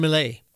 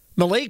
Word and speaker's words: Milei: Javier Milei. It's spelled M-I-L-E-I. Some circles, depending on which language you speak, it could be pronounced Javier Milei.